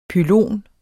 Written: pylon

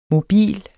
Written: mobil

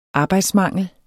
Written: arbejdsmangel

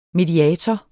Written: mediator

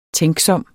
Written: tænksom

